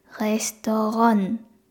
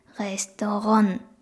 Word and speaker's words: Restaurant: レストランですね。レストラン何回も発音しちゃってください。で、最後の、えっ、と、てっていうのも発音しなくて大丈夫です。最後 N で終わっている感じですかね。